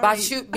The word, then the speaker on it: Bashu: Bashu. B-A-S-H-U.